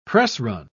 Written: pressrun

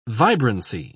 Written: vibrancy

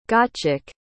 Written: gottschalk

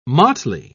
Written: motley

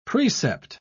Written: precept

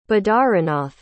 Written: badarinath

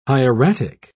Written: hieratic

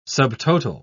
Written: subtotal

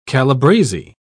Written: calabrese

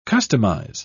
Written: customize

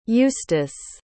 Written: eustace

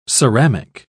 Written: ceramic

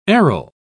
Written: aril

